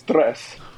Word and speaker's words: Tres: So it's Tress. You got to roll the R's a little bit.